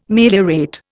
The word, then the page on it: meliorate: Indian